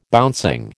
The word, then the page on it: bouncing: American English